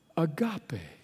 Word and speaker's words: agape: The Greek word of which it is a form is it's pronounced this way. It's the word agape.